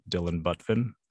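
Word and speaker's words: Dillon Butvin: My name is spelled D-I-L-L-O-N-B-U-T, V as in Victor, I, N as in Nancy, Dylan Butvin.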